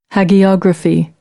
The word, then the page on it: hagiography: American English; ɑː - ɒ